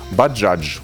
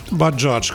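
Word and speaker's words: Bajaj: Я специально забил в поисковик, чтобы научиться произносить название этого мотоцикла. Баджадж. – Баджадж, конечно. – Вот Баджадж.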